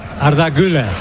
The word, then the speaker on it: Arda Güler: Arda Güler.